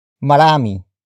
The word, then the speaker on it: marami: For example, marami, marami, marami. Marami is spelled as M-A-R-A-M-I.